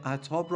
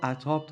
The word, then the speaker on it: عتاب: این واژه عتاب رو عتاب تلفظ کنید بهتره، عتاب هم میگن ولی درستش عتابه.